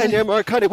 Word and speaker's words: Ennio Morricone: Is that the right pronunciation, Morricone? – Ennio Morricone. Well, yeah. Depends how Italian you want to pretend to be.